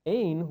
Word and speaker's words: Ein: So we have Ein, which is a fountain, kind of like, you know, pronounced like ain't without the T.